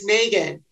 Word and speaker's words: Meaghan: It's Meaghan.